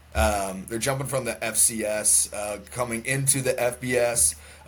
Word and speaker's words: FCS: They're coming up um, from the uh, FBS last year or FCS. Did I say that wrong? – Uh, FCS.